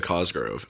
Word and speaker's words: Cosgrove: Oh, Miranda Cosgrove. Oh. – Is that how you say her name? – Cosgrove. Well, that's how it's spelled. – Cosgrove. – Just the one R.